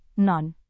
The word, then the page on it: non: Indonesian